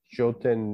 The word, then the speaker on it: Jotin: Yeah, it is uh, Jotin. It's just phonetically Joe and then Tin what does it mean?